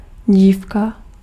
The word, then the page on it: demoiselle: /də.mwa.zɛl/; France; French